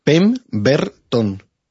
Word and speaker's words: Pemberton: Pemberton. – Ah, vale, entonces lo has dicho bien.